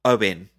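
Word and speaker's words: Eoghan: Dinah Vickers, Ergen Quick. Yeah. Is that how you say his name? – Owen. – Is it Owen? – Yeah, it's Irish.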